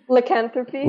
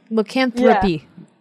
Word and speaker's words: Lycanthropy: And how do I pronounce the title of the song? – Lycanthropy. – Lycanthropy? – Lycanthropy. Yeah. There's an R in there.